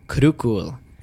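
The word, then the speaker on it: Krokhol: Event of our 3-week break south of Oslo, Norway, the number one disc golf course in the world, Krokol, as it's pronounced correctly. – That's how you say it? – Krokol. Yes. – You take both the O's and just switch them to U's.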